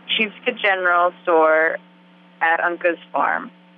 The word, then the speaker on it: Sheepscot: So if somebody wanted to come and visit you, they would find you on Sheepscot Farm, Sheepscot, wait, how do I pronounce it? – Yes, you're right, Sheepscot General.